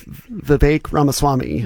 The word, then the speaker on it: Vivek Ramaswamy: Also, just to make sure, his name is Vivek Ramaswamy. Mm-hmm. Acton mispronounced that.